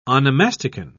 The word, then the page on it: onomasticon: British English